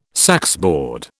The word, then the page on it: saxboard: British English